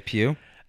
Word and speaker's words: Pugh: Pugh? – I would say Pugh.